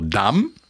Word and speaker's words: Dumb: Bei Wörtern, die Buchstabenkombination MB enthalten, vor allem am Ende, wird das B nicht ausgesprochen. Der Nirvana-Song heißt also Dumb, der Stones-Song Under My Thumb.